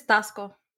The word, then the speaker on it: Taxco: On the 8th of December, the town of Taxco, it's T-A-X-C-O, I don't know if I'm saying that right. – Yeah, I think it's Taxco.